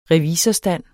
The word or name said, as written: revisorstand